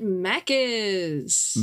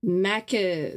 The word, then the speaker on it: Maccas: I do know that in Australia we call it Maccas. – Mackers? – Maccas. – Maccas? Not even with that rolled R. Yeah, I didn't even Maccas.